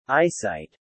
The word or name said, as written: eyesight